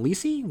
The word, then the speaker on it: Lisey: Our main character's name, Lisey, which rhymes with CC,